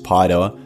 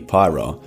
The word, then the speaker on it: Paeroa: Paidoa, that's spelled P-A-E-R-O-A, and technically pronounced Paidoa, or alternatively Pyro in the commonly used vernacular.